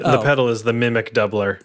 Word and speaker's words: Mimiq: Mimic. – It's like a k. – Mimic. M-I-M-I-Q.